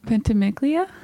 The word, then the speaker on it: Ventimiglia: Is it Ventimiglia? Do you pronounce the G? – Let me see it. No, it's Milo Ventimiglia.